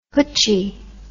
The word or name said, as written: hutchie